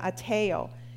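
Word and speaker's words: ateo (αἰτέω): It's the word ateo. It's how you say it, ateo.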